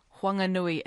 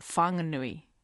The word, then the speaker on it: Whanganui: Just to make it clear, even though the H is going to be, or is in the name, it's still pronounced Whanganui. It's not Whanganui. – That's quite correct. It's what we call, I suppose, or the linguistics might call the glottal stop, eh? We don't say Whanganui or Whanganui.